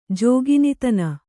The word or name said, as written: ಜೋಗಿನಿತನ